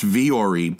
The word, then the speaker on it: Vuori: And it's pronounced Vuori, but it's spelled V-U-O-R-I.